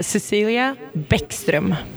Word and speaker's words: Bäckström: Uh, so it's uh, Cecelia Bäckström.